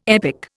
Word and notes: epic: Indian

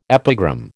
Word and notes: epigram: American English

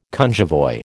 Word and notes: cunjevoi: American English

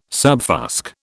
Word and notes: subfusc: British English

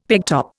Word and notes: pegtop: Indian

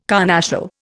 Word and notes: carnassial: Indian

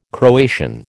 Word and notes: Croatian: American English

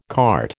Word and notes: cart: American English

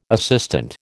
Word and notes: assistant: American English

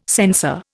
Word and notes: sensor: Indian

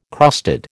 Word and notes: crusted: American English

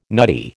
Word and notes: nutty: American English

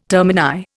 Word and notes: termini: Indian

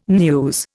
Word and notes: news: Indian

